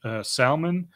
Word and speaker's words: Zalmon: Then Abimelech went up to Mount Zalmon, it's pronounced Salmon, some translations. Sometimes it's spelled with an S, sometimes it's spelled with a Z.